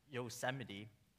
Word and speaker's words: Yosemite: which is a vertical rock formation in Yosemite National Park.